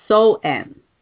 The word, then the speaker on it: Samhain: Halloween, like I said, originated back to that ancient Celtic festival of the Samhain. And I guess it pronounced as So-en.